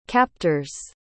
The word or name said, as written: captors